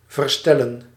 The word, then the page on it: remédier: [ʁǝ.me.dje]; France